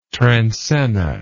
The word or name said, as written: transenna